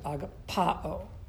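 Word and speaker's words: agapao (ἀγαπάω): Do you see that accent mark over the A, the alpha? You emphasize it, agapao. That's how that's pronounced, okay?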